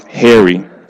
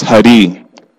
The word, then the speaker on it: Hari: The robber was actually a well-known criminal whose name was often mispronounced Harry. His name was actually pronounced Hari, which means he who removes all obstacles to spiritual progress.